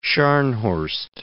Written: Scharnhorst

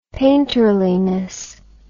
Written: painterliness

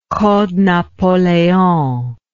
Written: Code Napoleon

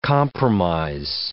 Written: compromise

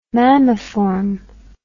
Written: mammiform

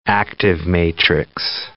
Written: active-matrix

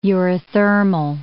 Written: eurythermal

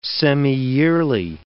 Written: semiyearly

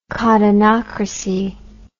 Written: cottonocracy

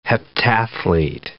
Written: heptathlete

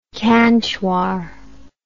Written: Cantuar

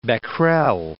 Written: Becquerel

